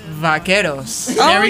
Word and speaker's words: vaqueros: Vaqueros.